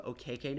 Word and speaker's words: Okeke: Yes, that's actually one of the things we learned from me today is Chuma is changing how he pronounces his last name to kind of fit more of his Nigerian descent. So it's Chuma Okeke now.